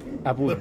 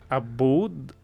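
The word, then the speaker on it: Aboud: Abud.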